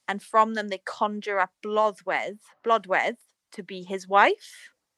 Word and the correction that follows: Blodeuwedd: So that's not Blodeuwedd, though, is it? – Blodeuwedd. Yeah, sorry, I'm saying it wrong.